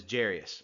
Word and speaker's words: Jairus: As I was reading it, I pronounced his name Jairus, but you might know it as Jarius. I don't know exactly why. It looks like it should be pronounced Jairus, but I've always heard it as Jarius. So if you call him Jarius, if you call him Jairus, I don't think that's too much of an issue.